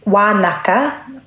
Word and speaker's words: Wānaka: My understanding is that, yes, it's Wānaka, which is the Ngaitahu pronunciation for Wānanga. And Ngaitahu, a lot of our NGs are pronounced as Ks.